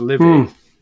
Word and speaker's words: Livy: Now I've been calling him Levi, which I think it's Livy.